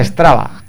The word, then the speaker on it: Strava: ¿Cómo se escribe exactamente? Estrava. Con D. Estrada. Con V. Estrava. E, Estrava, con V.